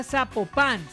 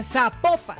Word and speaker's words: Zapopan: Oiga, nos escriben, nos escriben los oyentes y que no se, no se pronuncia Zapopan, sino que se pronuncia Zapopan.